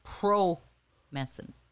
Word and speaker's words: Promescent: We're pronouncing it wrong, too. It's Promescent. – Promescent.